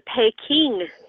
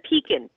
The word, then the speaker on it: Pekin: The Pekin duck, which is not the Peking duck, that's not the correct pronunciation, it's Pekin, P-E-K-I-N.